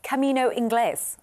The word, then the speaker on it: Camino Inglés: But have you heard of the Camino Inglés? I don't know if I said that right. – That sounded good.